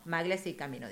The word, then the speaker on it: Maglia Ciclamino: Ahora sí lo dije bien. Maglia Ciclamino, dice.